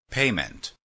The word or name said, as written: payment